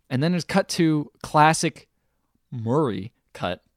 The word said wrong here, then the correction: Maury: Still said it wrong. – Man, how do I say it? – Maury. There you go.